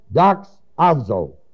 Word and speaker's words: doxazo: The word glorify is the word doxazo, D-O-X-A-Z, long O, transliterated into our word doxology.